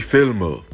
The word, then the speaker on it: Filme: Die Filme. And that would be capital F-I-L-M-E.